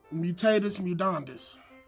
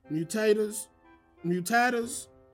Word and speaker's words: mutatis mutandis: Mutatis mutandis? – Correct. – Okay, whatever. – Oh, if I got that shit right, hell yeah. Mutatis mutandis, nigga, get it in.